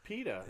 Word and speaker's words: PETA: It's PETA. – Is it like saying Peter in British? PETA! Yeah, PETA. – Like a PETA sandwich? PETA bread? – Guacamole sandwich?